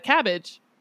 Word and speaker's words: CABG: And I learned in my research that a cabbage is, in fact, the same thing as bypass surgery. – Ah, I did not know that. So cabbage is also not spelled like cabbage. It's spelled C-A-B-G.